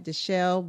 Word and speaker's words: Deshelle: Yes, they can follow me on Facebook at Deshelle Burrows, APCNCC. My name is spelled D as in Delta, E, capital S-H-E-L-L-E, Burrows, B as in Baker, U-R-R-O-W-E-S, and that's A-P-C-N-C-C right behind it, as well as my Instagram is at Sex Mommy Wellness.